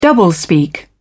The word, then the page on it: doublespeak: British English